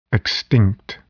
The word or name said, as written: extinct